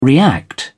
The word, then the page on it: react: British English